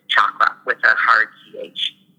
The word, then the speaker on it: chakra: It actually is pronounced chakra clearing. – Cha, like chair. – For all parts, yeah, with like CH, with like a hard CH. But the common way of saying it is chakra.